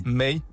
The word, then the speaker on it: Me2y: May. May, ouais. May, ok. M-E-2-Y.